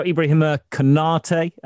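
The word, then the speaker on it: Ibrahima Konaté: So Ibrahima Konate, I think that's the correct pronunciation. Simon Hughes will have it down to a T. Another centre-back for Liverpool's squad. Couldn't find one in January. I mentioned this to David before. It's important that the planning's being done, though, at this stage, isn't it? Great exclusive from David there. What are your thoughts on this? – Well, I think you got your pronunciation right, so that's a good start.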